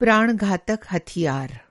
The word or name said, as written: प्राण-घातक